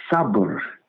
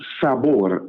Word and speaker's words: sabr: The characteristic or attribute of Allah, of which we speak in these brief moments this morning, is the characteristic and attribute of sabr, or sometimes pronounced sabor or sabur.